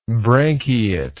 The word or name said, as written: branchiate